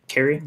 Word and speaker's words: Cary: Hopefully, I'm saying that right. Cary? Yep. Not Cary. – Yeah, yep. Cary. – Okay.